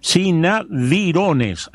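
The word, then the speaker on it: Sanavirones: Los más destacados fueron los tenocotés, se dedicaban mayormente a la agricultura, los lules, eran nómades y cazadores, los cinaborines, agricultores y alfareros, no sé si lo dije bien, cinadirones, ahora sí, Franco, cinadirones, agricultores y alfareros.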